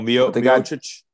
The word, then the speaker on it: Miocic: Oh, Miocic.